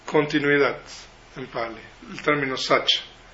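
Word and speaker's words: sacca: entonces se convierte en sacha en pali. La C en pali se pronuncia como cha, siempre.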